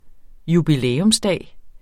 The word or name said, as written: jubilæumsdag